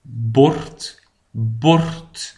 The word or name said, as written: het bord